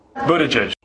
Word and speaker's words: Buttigieg: Well, let's listen to how he says it. Buttigieg.